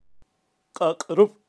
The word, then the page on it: kaqrub: Kaqchikel